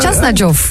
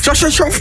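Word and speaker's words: Khachanov: Chasnajov. – Chasnajov. – Volgens mij was dat vlekkeloos uitgesproken.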